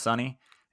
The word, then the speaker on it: Sonee: So she's on Spotify, S-O-N-E-E, pronounced Sunny.